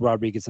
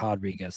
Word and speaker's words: Rodriguez: And I think he's going to win this fight over Rodriguez very easily, not least because its name is pronounced Rodriguez and not Rodriguez.